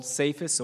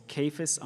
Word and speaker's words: Cephas: but you will be called Cephas," or Kephas, I'm told, is the appropriate pronunciation, which means Peter.